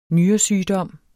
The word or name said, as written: nyresygdom